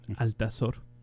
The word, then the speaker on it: Altazor: And then everything changed because I was reading a book called Altazor by Vicente Huidobro. And that's how it's pronounced also in English, Altazor.